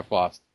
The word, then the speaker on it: Faust: Am I saying her name right? Is it Faust? – I think. – Yeah, Faust.